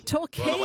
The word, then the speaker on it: Torquay: Let's talk. Talk, is it? – Torquay. – Well, it's spelt talk on my screen, like motor talk, but I thought, it might be Torquay, but okay.